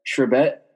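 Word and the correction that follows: Chrebet: Did I say that right? – Wayne Krebet. That's very, very recent.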